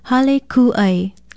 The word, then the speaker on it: hale kū'ai: Spell it H-A-L-E, hale, and K-U-okina-a-i. Then put a makron or kahako over the letter U. Pronounce it hale kū'ai. Hale kū'ai.